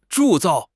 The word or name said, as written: 铸造